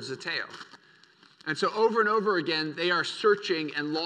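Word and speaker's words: Zeteo (ζητέω): Zeteo is how you say it.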